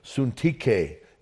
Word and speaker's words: Syntyche: And the word is actually, her name is pronounced Syntyche in the Greek.